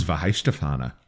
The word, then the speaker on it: Weihenstephaner: But how do you pronounce the name of this beer? – This is Weihenstephaner. Weihenstephaner.